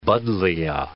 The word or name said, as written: buddleia